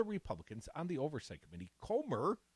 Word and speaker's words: Comer: Something like Comer, like rhymes with Gomer, maybe. – Like Homer, Kentucky. But Comer.